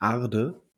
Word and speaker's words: Arde: Äh, eine, eine Berliner Band, eine deutsche Band, nennt sich Arde. Also, wie ich es auch gerade ausgesprochen habe, wie der Sender mit dem E am Ende dran.